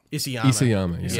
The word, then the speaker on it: Isayama: Isayama.